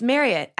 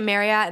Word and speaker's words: Marriott: It's Marriott rhymes with chariot.